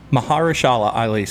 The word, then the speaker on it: Mahershala Ali: Mahershala Ali sounds right.